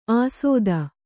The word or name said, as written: ಆಸೋದ